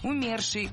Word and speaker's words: умерший: В этом слове ударение должно падать на второй слог. Умерший, умершие, умерших.